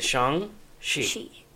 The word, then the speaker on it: Shang-Chi: And that's how you say it. Shang-Chi. – If you're saying Shang-Chi, you are saying your life wrong. Okay. – Well, you're saying it wrong. Right. I didn't know how to say it either.